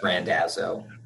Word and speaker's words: Randazzo: Did I say your last name right? I asked you before, and now I feel like I just mispronounced it. You got it. You got it correct. It's Randazzo. – Randazzo.